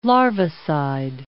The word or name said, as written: larvicide